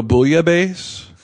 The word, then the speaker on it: bouillabaisse: They do a bouillabaisse. – Is that how you say it? – Yeah.